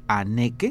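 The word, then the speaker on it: Hanneke: Aneke con H, H-A-N-N-E-K-E, Aneke, e, Van, V-A-N, Prosdig, P-R-O-O, o o s d Prosdig.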